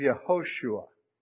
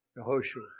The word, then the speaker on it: Yehoshua: Yeshua isn't itself in a contracted form of Yehoshua. Yehoshua.